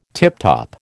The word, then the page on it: tiptop: American English